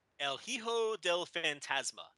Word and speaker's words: El Hijo del Fantasma: Pentagon Jr. and uh, Tejano Jr. El Hijo del Fantasma.